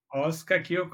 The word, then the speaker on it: OskaQ: OskaQ? – OskaQ Consulting, that's the right name.